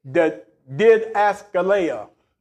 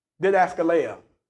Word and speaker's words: didaskalia: The Greek word for doctrine is didaskaleia. Didaskaleia. And it's spelled D-I-D-A-S-K-A-L-I-A, and it's pronounced didaskaleia.